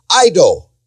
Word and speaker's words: eido: The word that is translated here to know Jesus, or they knew Jesus, is the Greek word eido, which is pronounced eido.